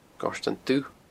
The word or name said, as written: Goirtean Dubh